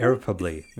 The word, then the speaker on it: irreparably: Irreparably? – Irreparably, yeah. – Irreparably. I said it right.